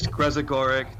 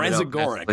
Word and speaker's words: Grezagoric: It's really not. It's Grezagoric. Don't be intimidated by it. Just sound Grezagoric. Like, okay, I can do that.